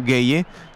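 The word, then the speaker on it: Guéhi: Po něm to bude Gueye. Ono se to píše Guehi, ale když jsem si poslouchal tohoto obránce Manchesteru City, jak sám sebe vyslovuje, tak řekl Gueye.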